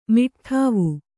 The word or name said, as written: ಮಿಟ್ಠಾವು